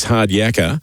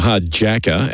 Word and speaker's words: Hard Jacka: Number six, Hard Yakka, or Hard Jacka as it's spelt, Olivia Chambers, has been $7 into $5.50.